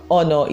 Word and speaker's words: honor: So as per Yoruba girl, I used to pronounce it as honor, like the H is pronounced because I do not want to be that regular Yoruba girl. but I have come to learn that the H in in honor is silent, so it is actually honor without the H.